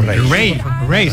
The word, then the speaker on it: Raye: Ray. Se escribe Raye. Raye con Raye.